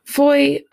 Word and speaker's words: Fowey: Foy, it's spelled F-O-W-E-Y, but it's pronounced Foy.